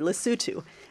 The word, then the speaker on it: Lesotho: And he mentioned one project in the tiny landlocked African country of Lesotho, which, if memory serves me, he said it correctly, Lesotho.